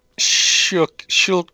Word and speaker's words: Schuylkill: Schuylkill River? – Schuylkill. – Schuylkill? No, there's a Y in there. – You don't pronounce a Y.